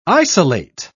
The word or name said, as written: isolate